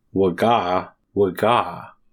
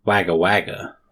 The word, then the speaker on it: Wagga Wagga: It's so what he said was it's pronounced waga waga. And I think when we were talking about it, we said wagga wagga.